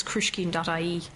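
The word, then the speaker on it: Cruiscín: It's krushkin.ie. And as you said, it's not spelt the way it's pronounced. It's C-R-U-I-S-C-I-N.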